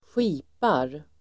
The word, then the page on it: skipar: [²sj'i:par]